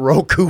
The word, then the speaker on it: Roku: I finally got Roku fixed.